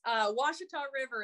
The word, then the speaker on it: Ouachita: Ouachita River? – Yes, it's hard to pronounce. Ouachita River, it's a river.